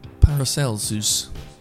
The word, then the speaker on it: Paracelsus: Paracelsus.